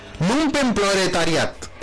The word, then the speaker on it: Lumpenproletariat: Palabra alemana. Lumpenproletariat. Lumpenproletariat. – No. – Ahí sí lo dije bien. Lumpenproletariat.